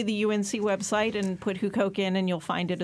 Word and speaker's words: Huqoq: It's at a site called Hukoke, which is spelled H-U-Q-O-Q.